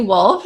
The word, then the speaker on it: Wolf: My name is Lindsay Wolf, just like the animal.